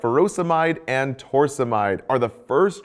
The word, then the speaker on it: furosemide: Potassium wasting ends in "-ide," like furosemide or hydrochlorothiazide. Both rhyme with dried.